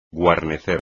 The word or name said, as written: guarnecer